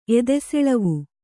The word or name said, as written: ಎದೆಸೆಳವು